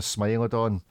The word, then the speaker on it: Smilodon: Is it Smilodon? Is that how you pronounce it? Smilodon watch. – Smilodon, Constantin Chaikin debuts as Smilodon watch.